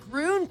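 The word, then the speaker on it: Gruene: Isn't that pronounced Green, Texas? – Not really. – I'm not sure. – In German, it's actually a German word, Wes. – Yeah? – And in German, Groon is Groon, right here. – Okay. – So it's actually Groon. – I know, but we pronounce it in the Texas way.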